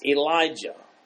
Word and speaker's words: Elijah: It's a transliteration to Greek and then to English. It's Elijah, is the correct pronunciation.